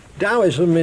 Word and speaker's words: Taoism: In fact, it's even difficult to know how to pronounce it, because although it is often spelt with a T, T-A-O-I-S-M, it is usually pronounced as though it were a D, the result of which some people write it with a D. As long as you know that when we're talking about T or D-A-O-I-S-M, whether we pronounce it Taoism or Taoism, we're talking about the same thing, then we shan't go far astray.